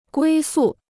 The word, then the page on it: 归宿: guī sù